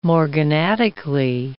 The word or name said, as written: morganatically